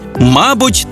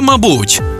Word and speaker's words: мабуть: Ось 5 українських слів із подвійним наголосом. Байдуже, байдуже, завжди та завжди, мабуть та мабуть, також і також, помилка і помилка.